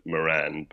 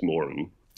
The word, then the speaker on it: Moran: So it's the stereotype that black books, Dylan, everyone over here pronounces it Moran, but I know in Ireland it's Moran.